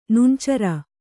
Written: ನುಣ್ಚರ

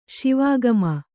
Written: ಶಿವಾಗಮ

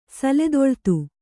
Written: ಸಲೆದೊಳ್ತು

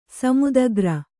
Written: ಸಮುದಗ್ರ

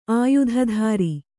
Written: ಆಯುಧಧಾರಿ

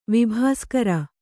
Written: ವಿಭಾಸ್ಕರ

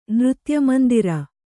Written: ನೃತ್ಯಮಂದಿರ

